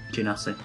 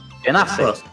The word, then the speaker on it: Genasi: He's a genocide. – A genocide? – Did I say that right? – Is that mass? – Genasi. – Genasi.